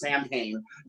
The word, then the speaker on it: Samhain: So like, you know, Halloween is Samhain, but it's pronounced Samhain.